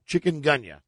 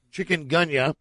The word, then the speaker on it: chikungunya: Now, how do you pronounce C-H-I-K, chick, U-N, chicken. – Chikungunya. – Thank you. Mm. Chikungunya.